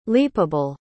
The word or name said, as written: leapable